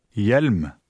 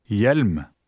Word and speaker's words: hjelm: Note that when a word begins with an H-J combination, the H is silent. Try word 5. – Hjelm. Hjelm.